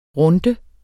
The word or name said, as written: runde